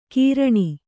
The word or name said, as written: ಕಿರಾಣಿ